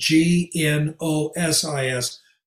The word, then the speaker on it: gnosis: It's spelled G N O S I S. Gnosis, that's where we get Gnostic, and that means knowledge.